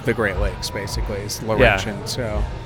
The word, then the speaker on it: Laurentian: Laurentian. – Laurentian, yes.